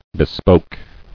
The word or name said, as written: Bespoke